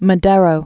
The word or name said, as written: Madero